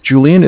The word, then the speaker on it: Julian: Okay, my name's Julian. It's J-U-L-I-A-N.